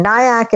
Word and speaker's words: Nayak: So everything on every social media, whether it's Facebook or Twitter or Instagram or my website, it's just Taniya Nayak as one word. But my name is spelled totally weird. It's my mother's fault, but she threw an I in there. So it's T-A-N-I-Y-A. And then Nayak is like kayak with an N. So N-A-Y-A-K. So T-A-N-I-Y-A-N-A-Y-A-K.